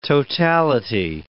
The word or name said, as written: totality